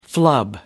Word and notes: flub: British English

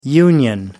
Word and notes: union: American English

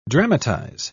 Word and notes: dramatise: British English

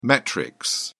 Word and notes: metrics: British English

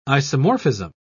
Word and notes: isomorphism: British English

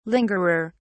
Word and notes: lingerer: British English

